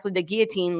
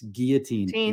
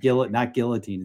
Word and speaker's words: guillotine: And my staff had to be like, it's guillotine, not guillotine. It's guillotine.